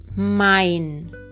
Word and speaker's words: mein: Por ejemplo, en las palabras drei y mein, donde aparece la EI en ambas palabras, esa EI no se pronuncia drei ni mein, sino ai drei y mein. O sea que la unión de la E y la I siempre se pronunciará i, como en drei, tres, y mein, mío.